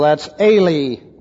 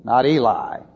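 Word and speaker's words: that's Eli (אֵלִי): E-L-I. And that's a long E, so that's Eli, not Eli.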